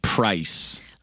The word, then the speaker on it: price: Notice the difference, Sandra, in pronunciation between prize. – Con una Z escrito, which means premio. – And price.